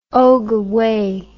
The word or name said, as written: ogooue